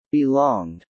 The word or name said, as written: belonged